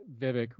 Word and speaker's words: Vivek: But you start getting Vivek rhymes with cake, and these who's just clearly a Trump surrogate, like at this point.